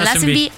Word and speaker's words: Lazenby: George Lazenby, ¿cómo se pronuncia? – Lassenby Lassenby Una película, edad 30 años.